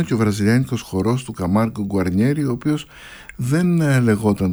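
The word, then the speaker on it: Camargo: Καμάργκο Καμάργκου, όπως προφέρεται στα βραζιλιάνικα, που είναι στην πραγματικότητα το όνομα το πατρικό της μητέρας του.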